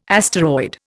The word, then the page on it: asteroid: Indian